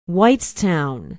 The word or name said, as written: whitestown